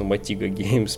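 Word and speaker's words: Motiga: Да, сэр Алекс, позволю дополнить, что также в этой новости шло комплектом э, распуск почти всей команды Матыга Геймс, ну Мотига Геймс правильно читается, mm-hmm.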